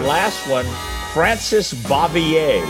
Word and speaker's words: Bavier: Oh, there is no way Aunt B's pronounced her last name Bavier. So it is. I'm telling you.